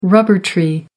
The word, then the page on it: rubber tree: American English